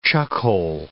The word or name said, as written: chuckhole